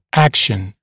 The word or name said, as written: action